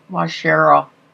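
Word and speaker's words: Waushara: Washara. – Oh, sorry. – Sorry. No, wait. How do you say it? I'm pretty sure it's Washara County.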